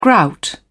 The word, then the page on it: grout: British English